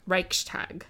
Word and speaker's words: Reichstag: In 1933, after the Reichstag fire, I apologize, if I'm butchering this German. – Reichstag.